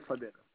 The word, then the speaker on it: Fadel: Bien sûr, mon nom c'est Salmi, qui s'écrit S-A-L-M-I. De prénom Oui. – Fadel, F-A-D-E-L.